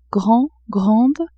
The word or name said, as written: grand-grande